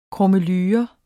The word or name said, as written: krummelure